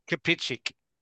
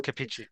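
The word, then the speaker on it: Kapičić: She then meets the mysterious and charming Oliver, played by Stefan Kapic. Anyone? – Kapichik. – Thank you. – Kapichik.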